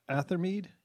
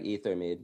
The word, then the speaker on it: Ethermead: It's like, but Athermead? – No, it's pronounced just like Ethermead.